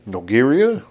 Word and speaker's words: Nogueira: Nogueira? – There you go.